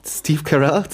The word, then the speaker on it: Carell: Wie würdest du ihn aussprechen? – Carell. – Steve Carell.